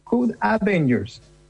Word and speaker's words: Code Avengers: Yo leí acá Code Avengers. Parece que se pronuncia como la D antes. – Code Avengers.